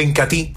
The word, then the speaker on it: Incatil: Se escribe Incatea, pero se pronuncia Incatil.